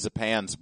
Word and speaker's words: Zapan: It's pretty dark that he takes Zapan's brain and, by the way, that romanization, Z-A-P-A-N, is very close to the way Japanese people pronounce Japan.